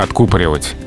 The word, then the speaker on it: откупоривать: Правильно, только откупоривать откупорить. Если вдруг по каким-то причинам надо обратно запаковать, то закупорить. А, в общем, в любом случае ударение на «у».